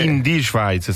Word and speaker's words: in die Schweiz: C'è questo titolo: Ein Kaltfront bringt kühleres Wetter in die Schweiz. Chiedo scusa per la pronuncia, ma ho studiato in Svizzera romanda. – Praticamente in die Schweiz sarebbe. – Sì, l'ho sì? detto giusto. – Sì. Giusto, sì.